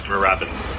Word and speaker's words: Moorabbin: I continue to mispronounce the name of a town in the outskirts of Melbourne, in Victoria, called Moorabbin. Like moo, like a cow says, and then rabbin, like you're going to say rabbit, then change the T to an N.